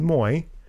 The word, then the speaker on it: Moi: Now that's Joey Moy, whose name is spelled Moi, but it is Moy.